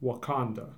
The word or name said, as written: Wauconda